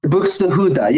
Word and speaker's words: Buxtehude: Buxtehude. Yeah, you said it right.